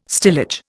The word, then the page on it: stillage: Indian